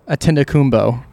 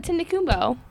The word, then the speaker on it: Antetokounmpo: Giannis Antetokounmpo. – Oh, Antetokounmpo.